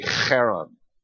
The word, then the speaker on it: Haran: Haran would be how we'd pronounce the son's name. Probably closer to the name of the town would be Haran. It's got that hard, guttural H.